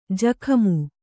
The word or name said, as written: ಜುಗುಮ